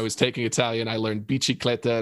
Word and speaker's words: bicicletta: So my favorite word is the Italian word for bicycle, bicicletta. Mm. I always really liked the way you pronounced C-I in Italian as chi.